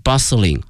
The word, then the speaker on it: bustling: bustling 这个词 b-u-s-t-l-i-n-g，记下来这个词 bustling 表示说这个街道特别的繁华，但是有一点哈，这里面这个 t 这个字母 t 是不发音的，叫 bustle bustling 啊，这是它发音上的一个细节哈，希望同学们注意一下 bustling street。